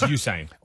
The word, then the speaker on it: Usain: But, but apparently Usain Bolt's not Usain Bolt. – It's Usain.